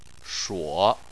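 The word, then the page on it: shuo: tone 3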